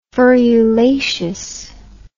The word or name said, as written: ferulaceous